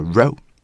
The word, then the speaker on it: row: 而 rouse 来自于 row R-O-W，那么这个词我们常读的方式呢叫 row，来表达一排或者说是一列。但是呢在这里边它要读成 row，因为它表达另外一个意思，指的是严重的分歧或者说是争执。